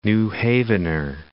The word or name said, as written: New Havener